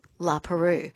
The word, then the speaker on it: La Perouse: And by the way, La Perouse has a silent S, so it is La Perouse.